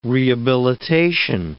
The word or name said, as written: rehabilitation